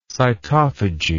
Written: cytophagous

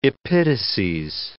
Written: epitases

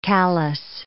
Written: callus

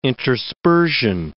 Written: interspersion

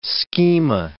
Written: schema

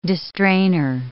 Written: distrainer

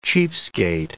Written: cheapskate

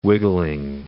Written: wiggling

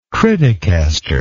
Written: criticasterism

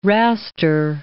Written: raster

